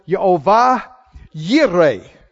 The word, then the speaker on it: Jehovah Jireh: Someone says, well, you know, uh, Jehovah, uh, Jireh, that's pronounced with a Y. So it's Yehovah Yireh. Great, but boy, it sure messes the song up, don't it?